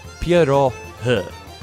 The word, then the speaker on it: pirohi: If you say it in Polish, it is pirogi. And of course, if you live in Pittsburgh, we just call them pierogies. But to be grammatically correct in the Russian language, the correct pronunciation is pirohi.